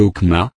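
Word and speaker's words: okma (חכמה): La sagesse vient du mot hébreu chokma prononcé okma, sagesse dans l'administration, et vient de la racine du mot chakam, okam signifiant enseigner la sagesse.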